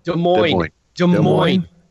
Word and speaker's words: Des Moines: Des Moines man. Did I say it right? – Des Moines. – Yeah, that's what I wasn't supposed to say the S.